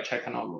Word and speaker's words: Çalhanoğlu: But are you going to pay $8,000 for Trent Alexander-Arnold, or rather pay 7.6 for Calhanoglu, right? Or Çalhanoğlu, right? I don't know why I can't pronounce that name today.